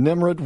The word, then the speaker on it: Nimrud: Nimrud.